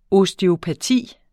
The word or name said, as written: osteopati